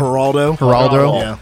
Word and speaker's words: Geraldo: My favorite thing about that whole thing, like, I remember when Michael was on Geraldo Rivera. That's how you say his name? – Geraldo.